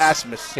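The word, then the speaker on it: Abmas: Why do they spell it like that? It's A-B-M-A-S, and it's Asmus. – Yeah. – Asmus.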